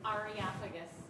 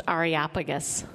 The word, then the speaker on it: Areopagus: So what is this Areopagus? How do you say that? – Areopagus. It's pronounced Areopagus. – Good. What she said, that's the right one.